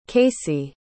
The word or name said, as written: kaycee